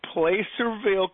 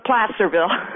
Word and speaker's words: Placerville: And this is from Placerville, California. Good morning. – Good morning. – Did I say that right? – No, it's Placerville.